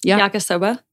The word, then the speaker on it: yakisoba: Yakisoba. – Yakisoba.